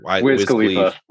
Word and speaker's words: Wiz Khalifa: I never say his name right. Is it Wiz Khalifa or Wiz Khalifa? – Thank you.